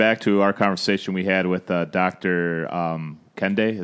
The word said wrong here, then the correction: Kendi: Is that right? Did I yeah, say that right? Uh, Doctor Ibram Kendi, who wrote um, "Stamped from the Beginning."